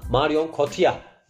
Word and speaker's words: Marion Cotillard: O yüzden en azından Marion Cotillard denildiğini bilmeniz işinize yarayacaktır ortamlarda.